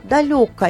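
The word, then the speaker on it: далёко: Оказывается, правильно было спрашивать, там, где вот она росла, правильно спрашивать, далеко ли с ударением на ё.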